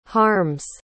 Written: harm's

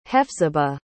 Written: Hephzibah

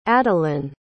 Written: adelyn

